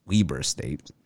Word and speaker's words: Weber State: We got the correct pronunciation. We Googled it and they had a thing where you click on it. It actually pronounces it for you audio style. And it was Weber State.